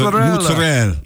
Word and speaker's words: mozzarella: The thick slice mozzarella. – Mozzarella. You got to pronounce it. Mozzarella.